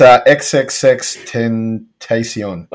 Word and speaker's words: XXXTentacion: It's XXXTentacion.